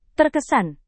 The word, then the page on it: terkesan: indonesian